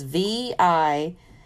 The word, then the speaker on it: Vijnanamaya: Vinanamaya Kosha, that's spelled, I'm probably even pronouncing it crazy, but it's Vijnanamaya.